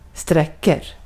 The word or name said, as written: sträcker